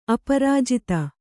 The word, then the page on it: ಅಪರಾಜಿತ: kannada